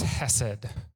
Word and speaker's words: Hesed: But that word is Hesed.